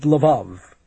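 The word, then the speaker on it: Levov: Swede Lvov. It rhymes with the love.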